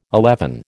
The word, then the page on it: eleven: American English